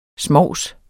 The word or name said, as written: smovs